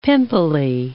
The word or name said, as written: pimply